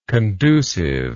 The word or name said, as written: conduciveness